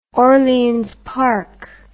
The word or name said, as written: orleans park